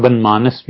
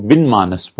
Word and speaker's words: بنمانس: نسناس بھی یہی ہوتا ہے، اور اسی طرح بنمانس بھی یہی ہوتا ہے. بہت سے لوگ بن مانس پڑھتے ہیں، وہ تلفظ ٹھیک نہیں ہے.